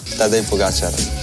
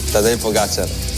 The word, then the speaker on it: Pogačar: Juanma dice que es aguda, mientras que otros es drújula, así que aquí tenemos cómo se dice de verdad. – Tadej Pogačar. Tadej Pogačar. Tadej Pogačar.